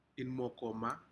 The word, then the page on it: inmokomma: Aneityum